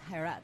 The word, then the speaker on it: Harat: Joining us now, director and editor for the short film Harat. Am I pronouncing that correctly? – Yes, Harat.